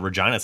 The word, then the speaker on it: Regina: It's such a large nation that you never know what might pop up as far as, like in Saskatchewan, they have the city that rhymes with fun, Regina, Saskatchewan.